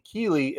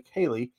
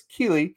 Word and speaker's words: Keely: I mean, Keely Ringo, and by the end of the time that we're done doing this, I'm going to have you saying Keely as opposed to Kaylee. So it's Keely.